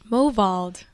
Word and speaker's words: Movold: All right, first thing I need from you, pronounce your last name for me. – Movold. – So I said, Jess, your last name is pronounced Movold, right? And what did you say? – Yeah, that's it. – But it's not. – That's the fancy version.